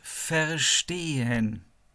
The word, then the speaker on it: verstehen: понимать, это по-немецки verstehen. Пишется V-E-R-S-T-E-H-E-N.